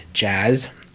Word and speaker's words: Jass: Katie, then Julia Othmer, Nathan Corsi, Jazz, which I have been mispronouncing every time I've ever said her name. The spelling is J-A-S-S. I've pronounced it as Jass, but it is indeed Jazz.